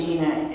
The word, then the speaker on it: Gina: And my first name, Gina, because it rhymes with my sister, whose name's Tina.